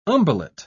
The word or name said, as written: umbellate